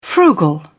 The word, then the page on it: frugal: British English